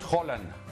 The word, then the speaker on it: Haaland: A ver, entre martes y miércoles vimos a Mbappé y vimos a Haaland, o como dice José Ramón, Haaland. Tiene una pronunciación maravillosa. ¿Cuál es la pronunciación correcta? – Erling Brut Haaland. – Haaland, perfecto.